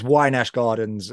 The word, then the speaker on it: Wynash Gardens: The music itself, like, I'm going to probably mispronounce this as well, but is it Wynash Gardens? – So it's Wynash Gardens, and Wynash. That's it's like an estate up the road from me.